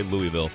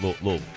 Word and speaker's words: Louisville: You know, people say Louisville, which is not how the locals pronounce it. It's Louisville.